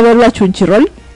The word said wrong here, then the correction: Crunchyroll: Crunchyroll. Exacto, Crunchyroll.